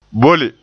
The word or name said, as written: bolle